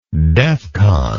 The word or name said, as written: defcon